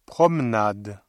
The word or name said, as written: promenade